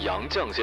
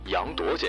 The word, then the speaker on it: 杨绛: 由于个人的疏忽，将杨绛先生读成了杨铎先生，实在是对逝者的不尊敬，且对听众的不负责任。